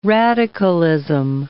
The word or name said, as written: radicalism